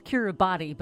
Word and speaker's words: Kiribati: It's spelled Kiribati, but it's actually pronounced Kiribati.